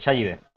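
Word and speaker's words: Xayide: Chayide. – Sí, es el mismo nombre, ¿eh? Y además con acento en la I.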